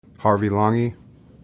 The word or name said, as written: Harvey Langi